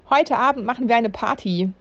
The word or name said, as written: Party